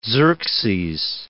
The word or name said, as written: Xerxes I